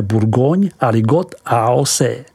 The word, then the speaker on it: Bourgogne Aligoté: Vyslovujeme Bourgogne Aligot AOC.